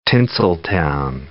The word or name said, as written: Tinseltown